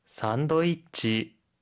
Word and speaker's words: サンドイッチ: 산도이치, 산도이치, 샌드위치, 샌드위치고요. – 호트도그, 호트도그. – 호트도그, 핫도그란 뜻이에요. 선생님, 혹시 발음 팁 있을까요? – 샌드는 산도라고 발음하는 점 주의해 주시고요.